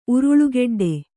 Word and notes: ಉರುಳುಗೆಡ್ಡೆ: uruḷugeḍḍe

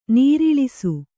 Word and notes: ನರಳಿಸು: naraḷisu

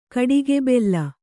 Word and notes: ಕಡಿಗೆಬೆಲ್ಲ: kaḍigebella

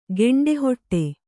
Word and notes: ಗಂಟುಹೊಡೆ: gaṇṭu hoḍe

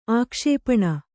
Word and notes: ಆಕ್ಷೇಪಣ: ākṣēpaṇa

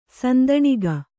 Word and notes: ಸಂತಾನಿಕೆ: santānike

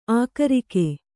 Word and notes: ಆಕರಿಕೆ: ākarike

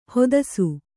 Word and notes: ಹತ್ತಿಸು: Kannada

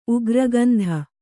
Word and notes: ಉಗ್ರಗಂಧ: ugragandha

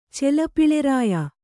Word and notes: ಚೆಲಪಿಳೆರಾಯ: Kannada

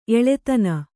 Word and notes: ಎಳದೆನೆ: eḷadene

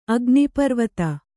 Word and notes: ಅಗ್ನಿಪರ್ವತ: agniparvata

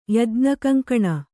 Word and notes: ಯಜ್ಞಕಂಕಣ: Kannada

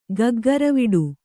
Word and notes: ಗಗ್ಗರವಿಡು: Kannada

